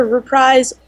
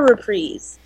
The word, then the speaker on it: reprise: And to interject with a verdict, Merriam-Webster says that it can be either reprise or reprise. – If you want to be wrong, it's reprise. – But reprise is the first pronunciation.